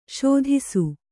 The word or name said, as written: ಸೋದಿಸು